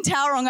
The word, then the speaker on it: Tauranga: I came directly from Tauranga. So I was preaching in Tauranga. I'm probably saying that, butchering that right now. Is that how you say it? Yeah, it's all right.